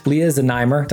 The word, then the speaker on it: Lia Zneimer: We're just meeting for the first time, Lia Zneimer.